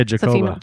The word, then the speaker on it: Jacoba: Jacoba? It's like Jacob, good. – But with an A. – Yeah, Jacoba.